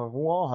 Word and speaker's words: Rouen: Also wir reden hier natürlich von Pierre Gasly, der auch noch sehr junge Fahrer, 1996 geboren in Rouen, Frankreich. Ich weiß nicht, wie man das ausspricht, vielleicht spricht man es aus Rouen oder irgendwie so aus. – Hast du schon richtig ähm, ausgesprochen? Rouen heißt es, glaube ich.